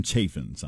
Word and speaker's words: Chaffins: And I did get a hold of him and ask him, does it rhyme with Ray or does it rhyme with calf? Is it Chaffins or Chaffins? He said, rhymes with Ray. So king of Coal Dust County, Adam Chaffins on Texas 95.7 The Armadillo.